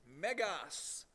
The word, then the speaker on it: megas: See, that word in the Greek language that's translated great, you know how that word's pronounced? Megas. That's where we get the word mega.